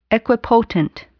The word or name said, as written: equipotent